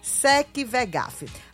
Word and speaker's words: SecVegaf: Esse é o momento de você fazer parte da família Sec Vegaf. Acesse o Instagram deles rapidinho pelo telefone, você consegue acessar o Instagram. E é fácil, tá? É @ SecVegaf. O V é mudo, tá? SecVegaf.